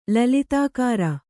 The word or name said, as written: ಲಲಿತಾಕಾರ